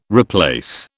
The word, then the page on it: replace: British English